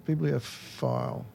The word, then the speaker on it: bibliophile: Or Phil. How do you say it? Bibliophile. Yes, I'll go with file.